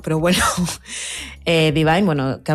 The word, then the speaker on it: Dvyn: Ella es Divine. Yo lo he dicho así, pero se escribe D-V-Y-N.